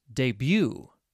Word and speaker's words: debut: The final word is debut. That's right, D-E-B-U-T. The musician debuted her first full-length album yesterday. What a crazy way to pronounce this word.